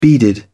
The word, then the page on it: beaded: British English